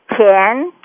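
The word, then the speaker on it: Qian: This is a tell me how to pronounce your name. – Chen. – Oh, I am saying it all right. Chen and Chen Yi. – Yeah, and that's spelled Q I A N Y I.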